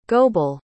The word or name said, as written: goebel